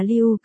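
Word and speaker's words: lưu (六): Số 6 trong tiếng Trung lưu đọc là lưu, gần với cách đọc của từ new, new.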